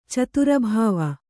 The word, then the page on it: ಛತ್ರಭವ: chatra bhava